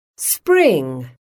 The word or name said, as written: spring